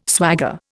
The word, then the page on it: swagger: Indian